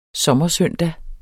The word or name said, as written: sommersøndag